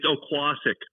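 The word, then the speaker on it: Oquossoc: Okusak. I don't know. – It's Oquassic. Oquassic, Maine. – Oquassic, Maine. Okay.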